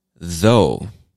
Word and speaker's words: though: Though, T-H-O-U-G-H, though. Now, this one is pronounced with a soft T-H sound. So it's more like a buzzing T-H sound. You're still going to put your tongue between your teeth to make the T-H sound, but it's not th, like thought or thorough or through.